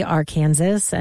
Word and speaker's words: Arkansas: A lot of people are still wondering why it's not pronounced like Kansas. Shouldn't it be Arkansas and not Arkansas?